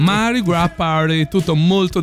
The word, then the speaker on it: Mardi Gras: Mardi Gras Party, visto che te mi dicevi da non pronunciare mai la S. – No, assolutamente, mai.